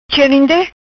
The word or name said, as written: Cherinde